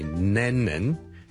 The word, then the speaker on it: Lennon: 第一個字咧就係、是、人名嚟嘅，John Lennon。 John Lennon。 John Lennon。一个英国嘅好出名嘅歌手同作曲家啦，Lennon，L-E-N-N-O-N，第一个系 L 音，所以唔好将佢读成 N 音，唔系 Lennon，系 Lennon，Len，Len，Len，Len，Len，Len，Len，Len，Len，后面嗰个先系 N 音，属 Lennon，仲要两个 N 音添，Lennon，第一个 L，Len，Len，Len，Len，Len，Len。